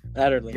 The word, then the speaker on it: Adderley: Adderley.